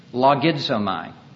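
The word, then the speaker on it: Logizomai (λογίζομαι): I'm going to pronounce it in the Greek only because you can hear an English, couple of English words in it. Logizomai. Logic. Logarithms.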